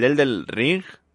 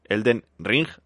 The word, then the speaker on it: Elden Ring: El Elden Ring, que no lo he dicho bien, pero bueno, Elden Ring, ahora sí, del creador de Juego de Tronos.